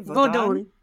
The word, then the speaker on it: Vodun: when you said Vodun, am I pronouncing that correctly? – Vodun. It's just voodoo, but with an N on the end.